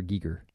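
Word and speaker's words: Giger: Call him Geiger. – Geiger counter, yes. Even though there's no E in there, it's just G-I-G.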